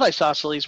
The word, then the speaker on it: Isosceles: And I think I read you like Sausalee's wine. Is that how you say it? – I absolutely love isosceles wine.